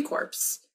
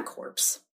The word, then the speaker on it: Corps: But in January 24th, 1979, he officially lists in the Marine Corps. It's the Corps. It's not a corpse. – I was going to say, I heard that. I was like, there's what, on corpse? – The P is silent.